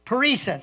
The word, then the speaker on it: parrhesia: Tell me, the, how do you say that again? parrhesia, thank you.